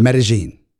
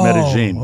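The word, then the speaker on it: Medellín: How do you pronounce it? – Medellin. – Oh, Medellin. – Okay. Medellin. Like Ibiza. – Yes. – Not Ibiza. – The Castilian pronunciation.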